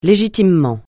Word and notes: légitimement: francais

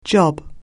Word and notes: job: anglais

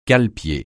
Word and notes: cale-pied: francais

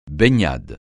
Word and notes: baignade: francais

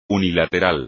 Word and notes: unilateral: espagnol

